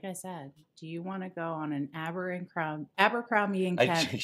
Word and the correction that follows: Abercrombie: She keeps adding an extra syllable in there. – Abercrombie.